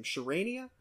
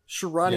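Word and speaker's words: Charania: How do you pronounce Shams' last name? Sharania. Sharania. Yeah.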